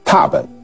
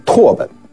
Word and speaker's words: 拓本: 六七八这三件拓本中保存了许多失传的王羲之的书记，共有 170 帖之多，是我们了解和研究王羲之最重要的历史文献。我们要在这里专门讲一下拓本啊，不要误念成拓本。我听很多专家都念拓本，很奇怪，念拓本的人啊特别的多。